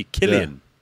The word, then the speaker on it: Cillian: C-I-L-L-I-A-N. – C-L-I? – C-I-L-L-I-A-N. How do you pronounce that? – The guy from Peaky Blinders, basically. – Is it Cillian or? – Yeah, you see, Killian. – Yeah. Oh, Killian. – You meant to say Killian.